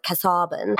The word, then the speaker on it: Casaubon: She marries this man whose name is Casaubon. Some people pronounce it Casaubon.